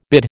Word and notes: bid: American English

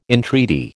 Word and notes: entreaty: American English

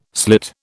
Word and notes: slit: British English

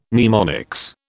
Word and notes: mnemonics: British English

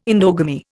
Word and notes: endogamy: Indian

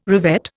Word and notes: revet: Indian

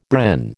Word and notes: Bren: American English

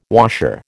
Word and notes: washer: American English

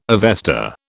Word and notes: Avesta: British English

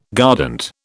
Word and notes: guardant: British English

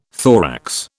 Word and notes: thorax: British English

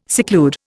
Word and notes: seclude: Indian

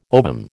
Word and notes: ovum: American English

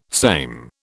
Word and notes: same: British English

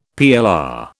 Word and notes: PLR: British English

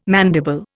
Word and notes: mandible: Indian

